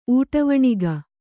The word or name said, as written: ಊಟವಣಿಗ